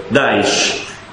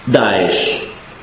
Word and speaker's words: Daesh: Daesh, cioè Daulah al-Islamiyya fi Iraq wal-Sham, Daesh. Ma per pronunciare Daesh hanno messo la E, Daesh, quindi un inglesismo.